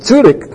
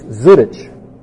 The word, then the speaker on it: Zurich: He says, oh, no, you're not saying it right. It's Zurich.